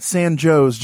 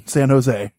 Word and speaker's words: San Jose: We're talking about the Winchester Mystery Mansion, this beautiful, beautiful mansion in San Jose, California, not San Jose, John, San Jose.